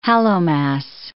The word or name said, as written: Hallowmas